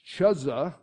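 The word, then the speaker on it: Chuza: And Joanna, the wife of Chuzza, is what I'm told the way you pronounce that.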